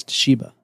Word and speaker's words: Toshiba: What is it? Toshaba. I'm so bad at pronouncing it. The worst part about it is because you've gotten it wrong three times, I'm probably going to get it wrong. I think it's Toshiba. – Toshiba.